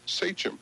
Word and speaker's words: sachem: the name of their office is spelled S-A-C-H-E-M. And I always pronounce it Sachem. – So did I. – But I was corrected today. It's Sachem.